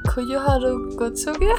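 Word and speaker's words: Koyoharu Gotouge: Und das Genre ist Fighting Shonen ja, und der, ähm, der Mangaka heißt Koyoharu Gotsuge.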